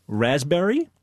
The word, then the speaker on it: raspberry: K-N-E-E-L, Neil. – Raspberry answer. – Silent right. letters. – Yes, silent letters. Right. Raspberry is? – The silent S. – P. – Or P. Sorry. – Silent P, right.